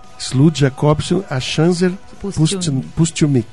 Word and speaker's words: Slud Jacobson Achanzer Pustilnik: Estamos aqui com a Rebeca, por favor, me ajude a pronunciar seu nome. Rebeca... – Slud Jacobson Achanzer Pustilnik. – Slud Jacobson Achanzer Pustilnik. – Isso.